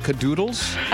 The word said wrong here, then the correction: caboodle: No, you have not been saying it right all morning. – What is it? – Caboodle. – Oh, the B. Caboodle. – B. Caboodle. – It sounds more natural to say cadoodle.